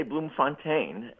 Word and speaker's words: Bloemfontein: Well, I usually say Bloemfontein, and, you know, his dad was one of these Brits who went in search of a better living.